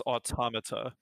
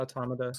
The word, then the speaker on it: Automata: Is that how you pronounce that? – It's Automata. – Yeah, Automata.